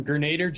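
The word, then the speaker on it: Gernader: I'm Grenader Jake.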